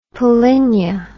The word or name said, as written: polynia